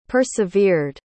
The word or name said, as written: persevered